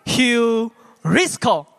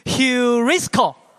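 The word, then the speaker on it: heurisko (εὑρίσκω): 所以我一开始在第一堂，我就教了我们的青青的特会的这些的年轻人们哈，我就教了他们的一个希腊字，那个字叫做“ 寻得”，那个字要怎么念？那个字叫做 “heurisko”。哎，亲爱的家人，来跟我念一遍呐，“heurisko”。来，跟我来念一遍，来，“heurisko”，就是寻得的意思。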